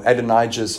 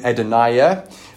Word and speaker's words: Adonijah: Now, just quickly on the name as I read, uh, when I read it, I wanted to pronounce the name Adonijah, but I spoke to some Hebrew scholars who are friends of mine, and they reckon the name needs to be pronounced Adonijah.